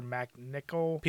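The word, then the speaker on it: MacNicol: And Peter McNoll, was it? How do you say his name? The guy from Ghostbusters 2. The guy who played Renfield in that movie. Peter McNichol. He was a good version of what you're trying to describe.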